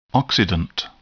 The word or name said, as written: oxidant